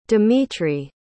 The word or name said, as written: dmitriy